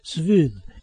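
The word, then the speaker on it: schwül: If there's an umlaut over the U in this word, then it is pronounced Svul, and means warm or humid. If not, it means gay or queer.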